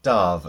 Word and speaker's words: Damh: D-A-M-H. It's pronounced Darv officially, is that right? – Yes.